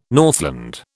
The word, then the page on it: Northland: British English